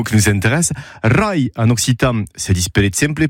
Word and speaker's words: Rai: Rai. Rai, ça s'écrit R-A-I. Rai.